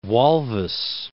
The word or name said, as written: Walvis Bay